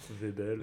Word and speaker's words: Waibel: Euh, et, donc, en fait, on va avoir une expérience d'un groupe de l'école polytechnique fédérale de Lausanne avec Webel, enfin, je ne sais pas du tout comment ça se prononce, mais W-A-I-B-E-L. – Hein. Webel. voilà.